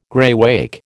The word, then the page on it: greywacke: American English